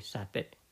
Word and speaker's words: sati: Spell S A T I. In my language, we pronounce it sati.